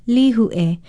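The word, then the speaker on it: Lihue (Līhuʻe): Write it out with a kahako over the first vowel and an okina before the last one. Then say it, Lihue. I'm Leilani Poliahu of Hawaii Public Radio, and Lihue is our Hawaiian word of the day.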